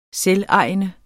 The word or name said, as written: selvejende